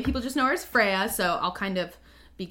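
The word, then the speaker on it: Freyja: So first and foremost, the medieval Norse pronunciation for her name is Freya, which I sometimes... – Freya? – Is Freya, yes.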